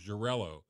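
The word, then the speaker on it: Xarel·lo: But the grape variety is Jurello. And that's spelled X-A-R-E-L-L-O.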